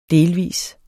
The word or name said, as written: Delvist